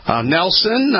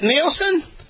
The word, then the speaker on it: Nielsen: Is it Nielsen? – Yeah, Nielsen Kellerman. – I just want to make sure. I don't want to get these ugly emails from your customers saying, hey, why don't you pronounce it right? – No, you got it.